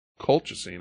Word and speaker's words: colchicine: Colchicine.